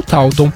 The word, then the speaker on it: Taunton: Mas eu falo Taunton.